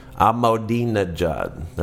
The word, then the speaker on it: Ahmadinejad: And then you start to get the Hugo Chavez's and the Ahmadinejads and Ahmadinejad.